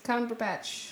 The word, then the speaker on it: Cumberbatch: Is that how you say? Cumberbatch. – There you go.